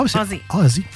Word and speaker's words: Aussie: Ah, Ozzy. – Mais ça s'écrit A-U-S-S-I-E.